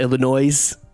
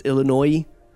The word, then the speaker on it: Illinois: Oh, it's not Illinois. It's Illinois.